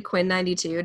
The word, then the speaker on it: Quinn XCII: That's, which is actually Quinn 92.